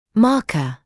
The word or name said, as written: marker